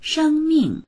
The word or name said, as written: sheng1ming4